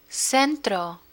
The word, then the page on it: centro: spanish